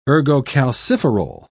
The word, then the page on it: ergocalciferol: British English